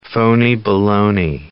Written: phony-baloney

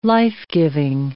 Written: life-giving

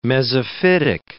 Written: mesophytic

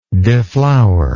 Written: deflowerer

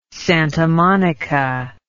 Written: Santa Monica

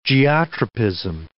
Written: geotropism

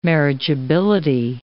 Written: marriageability

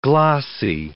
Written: glossae

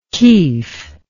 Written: kief

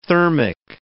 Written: thermic

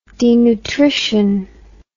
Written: denutrition

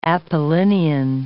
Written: Apollinian

